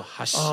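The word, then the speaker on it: 箸: 因为这个是一个音的一个不同，因为我们刚刚讲到筷子，筷子的日文发音叫做哈西啊，对对对对对对对。 – 但是如果你的音变成哈西，哈西就变成是桥了，所以你就可以去想象，呃，筷子形同等于就是桥。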